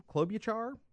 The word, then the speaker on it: Klobuchar: Senator Amy Klobuchar, I believe, Klobuchar is how you say her name, the government now accepts that Adam is actually a living, breathing person.